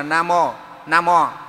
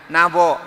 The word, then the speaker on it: Nam Mô: Người ta đọc bên kia, người ta đọc là Nam Mô. Thế thì bên này thì ông dịch là Nam Vô, hiểu không?